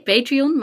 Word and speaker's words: Patreon: Het heet Patreon, maar de mensen die lid zijn heten patrons.